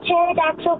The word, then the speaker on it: pterodactyl: Why can't you use pterodactyl go to the bathroom? – Ooh, good question. We don't know. Why? – Because the pee is silent.